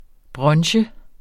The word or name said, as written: brunch